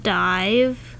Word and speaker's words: DIIV: Yeah, it's spelled D-I-I-V, so it's kind of like... – Div. – Div, yeah. – Like an HTML div.